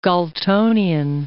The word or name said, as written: Galtonian